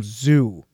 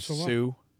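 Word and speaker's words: Suh: You call him Zoo. – Yeah, you say Ndamukong Zoo instead of Sue.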